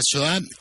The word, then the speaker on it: Shoah: ¿Qué se ha contado de la Shoah? ¿Qué es esto? ¿Qué significa esta palabra que se escribe S-H-O-A-H?